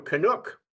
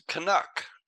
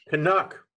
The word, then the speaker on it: Canuck: We're talking to a Canuck in Western Canada, a retired businessman there. Welcome to the show. – Well, you can at least say it right. It's a Canuck. – Canuck.